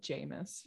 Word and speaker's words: Jamis: Oh, they end it after the fight with Jameis or Jamis. I don't know how you say it. – Oh, I said Jameis. – Jameis.